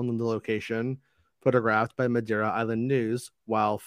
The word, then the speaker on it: Madeira: Last week, it was revealed that an unknown Star Wars project was set to film at Madeira Island, Portugal. Meg, do you have any idea if I'm saying that right? – Madeira Island, okay.